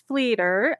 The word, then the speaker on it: Flieder: So it's Cherish Flieder, and it rhymes with leader, which was, it's really cool because it's the German word for lilac.